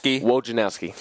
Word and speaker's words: Wojnarowski: Wojnarowski.